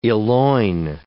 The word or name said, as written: eloign